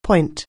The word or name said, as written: point